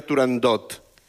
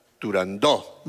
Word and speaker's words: Turandot: No se dice, no se debe pronunciar Turandot. – Ah, no, Turandó. – La ópera se titula Turandó.